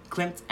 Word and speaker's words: Klimt: The art nerd in me loved all of the like the Klimt. Is that how you say his name? – Klimt, and then Warhol, and then Monet, and beautiful.